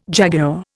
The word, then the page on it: jaguar: Indian